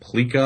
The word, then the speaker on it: plica: Another one is Mario Chalmers had arthroscopic surgery to repair an inflamed plica. I believe that's how it's pronounced, PLICA.